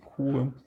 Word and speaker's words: Hue: Und ähm, ist in diesem, ich, wie spricht man es aus? Hue oder, oder Hue? Hue.